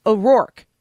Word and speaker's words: O'Rourke: I'm putting too many syllables in it. – Mm-hmm. – Yes, you are. – O'Rourke.